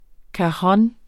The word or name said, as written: cajon